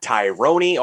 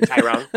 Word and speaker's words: Tyron: His name is Tyron Woodley. It's not Tyrone. It's not Tyron. It's not Tyrone, or however else you want to fuck it up. It's Tyron Woodley.